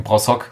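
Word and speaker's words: Broshog: Allerdings sind das auch Kräfte wie ein Michael André, Tim Broschok, Tim Brossock, so wird er ja richtig ausgesprochen, ein äh, Thomas Kotzian.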